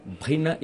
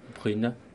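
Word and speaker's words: Bryne: Brina is the good pronunciation? – No, it's not. How is it? Brina. Brune? – Yeah, almost. A bit better.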